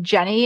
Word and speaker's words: Jennie: And it's just my name, Jennie, and it's spelled with an I-E. So J-E-N-N-I-E. And my last name, Juechter, J-U-E-C-H-T-E-R.